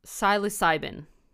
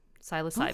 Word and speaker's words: psilocybin: Psilocybin. Psilocybin.